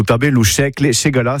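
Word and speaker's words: Segalar: C'est une ségaline, ségala, qui s'écrit en occitan avec un R à la fin, c'est un R muet.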